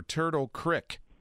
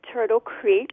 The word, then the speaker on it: Turtle Creek: Is it Turtle Creek or Turtle Crick? – I pronounce it Turtle Creek, but the lifelong residents perhaps say Crick.